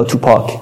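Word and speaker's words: توپاک: یا تو پاک در واقع جفت تلفظ میکنن تو پاک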